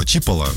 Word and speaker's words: Чиполла: Чиполла, вот, ударение так стоит.